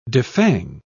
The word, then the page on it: defang: British English